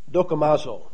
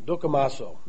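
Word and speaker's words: dokimazo (δοκιμάζω): The word examine here in 1 Corinthians chapter 11 and verse 28 is dokimazo. Dokimazo. It's spelled D-O-K-I-M-A-Z-O. Dokimazo is how I would pronounce it.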